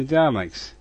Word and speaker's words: Dalek: fighting the Daleks? No, that does not bear thinking about. Bye for now. – Thank you, Ian. – I think you'll find it's pronounced Dalek.